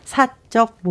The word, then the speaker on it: 사적: 같은 그 글자로 되어 있는데 발음이 다릅니다. 사적 모임과 사적. 사적 다릅니다.